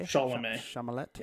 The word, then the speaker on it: Chalamet: Chalamet.